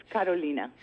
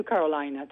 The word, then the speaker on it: Carolina: In Spanish, it's Carolina. – Okay, thank you. – Okay. But it's fine, it's fine. I'm used to Carolina, too.